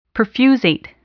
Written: perfusate

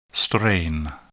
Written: strain

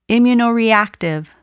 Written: immunoreactive

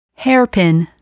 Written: hairpin

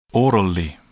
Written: orally

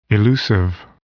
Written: elusive

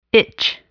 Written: itch

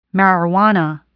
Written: marijuana

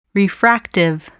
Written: refractive

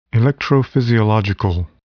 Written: electrophysiological

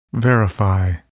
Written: verify

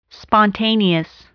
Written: spontaneous

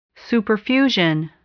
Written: superfusion

